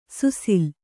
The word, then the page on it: ಸುಸಿಲು: kannada